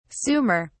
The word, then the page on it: sumer: British English